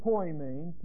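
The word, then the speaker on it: poimēn: This is poimen, P-O-I-M-A-N, or E-N, long E. And this is didaskalos, D-I-D-A-S-K-O-L-O-S.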